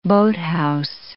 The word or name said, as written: boathouse